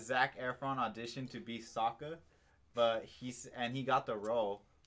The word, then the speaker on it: Sokka: They're never given a reason to care for Soku.